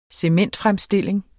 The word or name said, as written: cementfremstilling